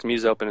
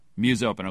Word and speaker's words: MuseOpen: Muse Open, or how do I pronounce it? – You know, domains are hard to come by, so anything works. Muse Open is what I go by. – Muse Open.